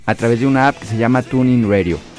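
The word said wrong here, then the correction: TuneIn: Se escribe T-U-N. – Tin, hombre. – Tune in. – Muy bien, ahí está, ya está.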